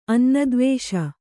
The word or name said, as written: ಅನ್ನದ್ವೇಷ